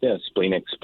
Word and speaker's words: splenic: Is it splenic? – Yeah, splenic.